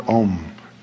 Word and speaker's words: Om: Then there may come the awakening of awe, a sense of mystery in the world, and that is when the sound Om is heard. The sound is pronounced starting in the back of the mouth, ah, ooh, mm.